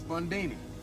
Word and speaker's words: Bundini: Bundini. Rhymes with Houdini, yeah, except that don't rhyme.